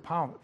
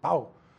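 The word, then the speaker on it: pão: And Marie and I, well, we would walk up to get some bread or, what is it, pau, that's how they say it, pau.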